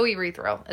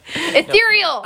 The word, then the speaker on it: ethereal: You are so urethral. Is that how you pronounce that? – Urethral? – Word? Urethral? Ethereal.